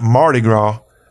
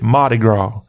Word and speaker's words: Mardi Gras: But if you're truly a Louisiana native and you're from the Big Easy, it's not Mardi Gras. There's no R in Mardi. It's Mardi Gras.